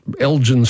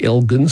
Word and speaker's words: Elgin: think, or, you know, Elgin Street in Carlton really mm-hmm. should be Elgin Street because yes. – it's named after Lord Elgin.